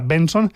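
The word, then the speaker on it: BNXN: Es Back Outside de Benson, que por cierto, Benson se escribe B-N-X-N, B-N-X-N, pero se pronuncia Benson, y SARS.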